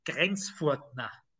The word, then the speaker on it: Grenzfurtner: It's just like some weird medieval way in German to sometimes you spell the T with an H, but it's not a th, you know, it's just a T. It doesn't so the correct Austrian German way to pronounce it is Grenzfurtner.